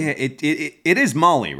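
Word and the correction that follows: Mahle: No, it's Mally. – Mally? – Okay, I just looked this up. Pronunciation rhymes with rally.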